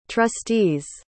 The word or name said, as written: trustee's